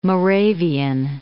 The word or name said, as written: Moravian Gate